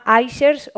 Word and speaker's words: iShares: Y voy a presentaros también eh, a nuestra marca de ETFs, que se llama iShares o Ishares, como lo queráis pronunciar iShares es la marca eh,